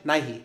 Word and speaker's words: Nighy: Nighy. That's how you pronounce it? – Yes.